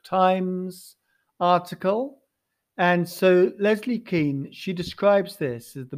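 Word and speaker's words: Kean: Leslie Keane, sometimes pronounced Kane, but I think it's Keane, who is, responsible for a large part for the supercharged nature of the revolution.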